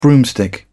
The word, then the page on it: broomstick: British English